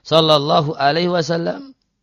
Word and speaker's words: sallallahu alaihi wasallam: Jadi ini bacanya jangan sad lam ain, atau dalam bahasa Indonesia singkatan solawat SAW itu jangan dibaca Muhammad SAW gitu. Namun Muhammad sallallahu alaihi wasallam.